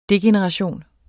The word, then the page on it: degeneration: ˈdegenəʁɑˌɕoˀn; degenəʁɑˈɕoˀn